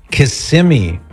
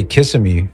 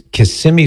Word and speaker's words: Kissimmee: This is one from Carmen in, I'm going to say it right, don't worry, Kissimmee, Florida. Don't you dare say Kissimmee, Florida, Cyrus, because you'll get corrected fast. I know. It's Kissimmee, Florida.